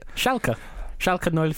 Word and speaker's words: Schalke: Schalke 04.